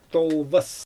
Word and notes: tolvas: Xyzyl

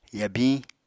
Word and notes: yabiñ: Apatani

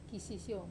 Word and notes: kasisiou: wamesa

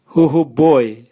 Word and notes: huhuboe: Raga

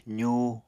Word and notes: zuu: apatani

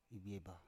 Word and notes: ibieba: Biak

